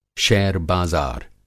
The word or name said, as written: शेयर बाज़ार